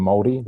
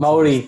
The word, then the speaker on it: Māori: I am Indigenous New Zealander, so I'm Māori. That's how you pronounce Māori.